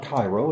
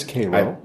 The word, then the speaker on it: Cairo: It's not Cairo, it's Cairo.